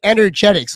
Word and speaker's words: Energetic: How do you pronounce the name? – Energetic. – So it's a silent E. – Yes. – So the silent, so it's Energetic. Okay, I'm just making sure that I'm saying it right.